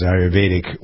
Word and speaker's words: Ayurvedic: As someone who was writing about Ayurvedic, you pronounce this any number of ways, but I pronounce Ayurvedic wisdom, Ayurvedic. And it involves checking in with yourself, knowing yourself.